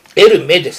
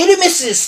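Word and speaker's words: Hermès: エルメです。 – はい、フランス人、フランス語は最後の文字は消し字で読まないの、発音しないの、だからエルメなのね。これエルメス素敵ねえなんつってるバーガー言ってんじゃねえとね、後ろからヘッドバスやってくださいそういうバカ女はね。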